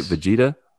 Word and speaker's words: Vegeta: Vegeta? – Yep, Vegeta.